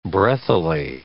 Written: breathily

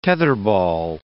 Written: tetherball

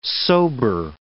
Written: sober

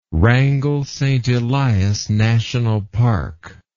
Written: Wrangell-St. Elias National Park

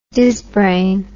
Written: dizzbrain

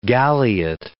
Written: galliot

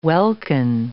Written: welkin

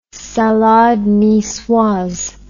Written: salade nicoise

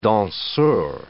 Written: danseur

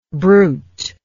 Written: bruteness

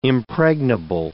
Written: impregnable

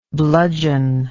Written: bludgeoneer

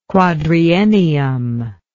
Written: quadriennium

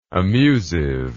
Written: amusive

